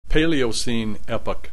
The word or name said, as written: Paleocene Epoch